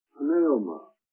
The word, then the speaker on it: pneuma: It's the word pneuma. P-N-E-U-M-A. We got our word pneumatic. Pneumatic is spelled with a P, you know.